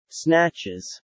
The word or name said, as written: snatches